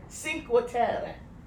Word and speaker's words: Cinque Terre: Cinque Terre.